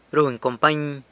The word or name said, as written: runy company